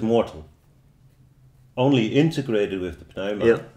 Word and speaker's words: pneuma: So they would say part of the problem is that the psyche got separated from the pneuma or pneuma. But it's you spell it with a P, the pneuma.